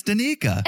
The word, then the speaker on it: Stanika: The way you pronounce her name is Stanika.